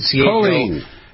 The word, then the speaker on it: choline: We got about a minute left here about choline. Is that how you pronounce it? – Choline.